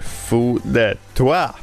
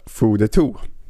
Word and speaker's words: fou de toi: Only in my head sjunger Fou de toi, som Martin nyss lärde mig att man säger på franska. Mycket vackert uttal. Jag ville säga Fou de tout. inte lika vackert uttal. Eh, det var inte alls rätt.